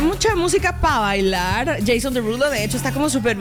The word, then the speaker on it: Desrouleaux: Entonces se escribe Desrouleaux, pero se pronuncia Derulo, digamos.